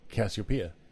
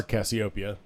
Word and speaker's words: Cassiopeia: And I wrote this whole Christmas story revolving around Cassiopeia. – Nice. – Or Cassiopeia, depending upon how you want to pronounce it.